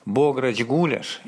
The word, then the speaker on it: гуляш: Бограч гуляш – именно такое ударение.